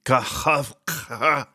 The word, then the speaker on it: Kakhovka: crucial Kakhovka Dam. That's exactly how you say it. Kakhovka Dam.